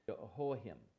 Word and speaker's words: Jehoiachin: His name in the Hebrew is actually pronounced Jehoiachin. And he was the king for a time.